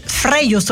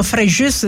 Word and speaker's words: Fréjus: Esatto, 155 anni fa apriva il traforo ferroviario del Fréjus o Fréjus, dipende un po' come lo volete pronunciare, alla francese, all'italiana.